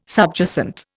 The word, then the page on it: subjacent: Indian